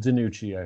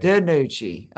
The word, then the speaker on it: DiNucci: Uh, I mean, Ben, what is it, Nanucci, right? And that how you say it, Nanucci? Uh, ben DiNucci, I think. DiNucci.